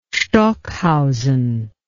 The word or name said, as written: stockhausen